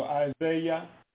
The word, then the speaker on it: Isaiah: So when we say Isaiah, anything with an I-A-H, actually the end of it is pronounced with Yah. So Isaiah.